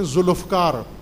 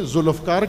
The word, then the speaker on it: ذوالفقار: یا جیسے ایک اور غلط تلفظ کرتے ہیں ذوالفقار, پنجابی میں ذوالفقار کہتے ہیں, عربی میں ذوالفقار, ذوالفقار دو لفظ ہیں, یہ ایک لفظ نہیں ہے, ذوالفقار غلط ہے, ذوالفقار, ذوالفقار, ذل زو ایک لفظ ہے, الفقار دوسرا لفظ ہے,